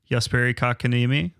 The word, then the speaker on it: Jesperi Kotkaniemi: The newest addition to the team, former number 3 overall pick, Jesperi Kotkaniemi.